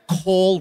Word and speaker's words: Cole: Would you agree that Cole rhymes with hole? – I would.